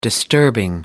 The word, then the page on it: disturbing: American English